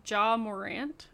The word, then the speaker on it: Ja Morant: The Grizzlies' Ja Morant, is that how you say his name? – Yeah.